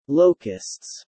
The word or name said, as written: locusts